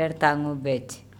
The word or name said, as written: Ertangobec